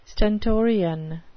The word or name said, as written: stentorian